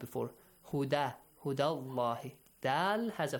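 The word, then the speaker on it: الله: The word Allah, the letter lam in the word Allah is pronounced heavily because of the...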